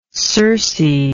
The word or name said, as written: circaean